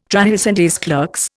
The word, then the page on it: trahison des clercs: Indian